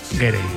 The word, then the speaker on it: Gere: Estaba pensando yo que si te apellidas Ger, bueno, ¿cómo se dice Ger o Gerde? – Gere. Me ha hecho gracia porque has dicho Richard Gere y luego el hijo era Gere. – Yo creo que es Richard Gere.